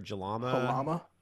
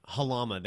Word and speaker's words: Jalama: It's like Jalima or Jalama. – Halama. – Thank you. Yeah, that was ignorant on my part. Halama. It starts with the J, though. That's why I was pronouncing the J.